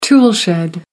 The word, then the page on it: toolshed: American English